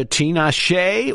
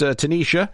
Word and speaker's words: Tinashe: It's either Tanisha or Tinashe or Tinash. Could be any one of the above. But we've heard it pronounced Tanisha. Although she spells it T-I-N-A-S-H-E.